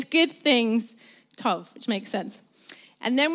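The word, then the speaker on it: tov (טוב): So I looked up the word goodness in Hebrew, and it's pronounced tov. Can you say that with me? Tov, lovely.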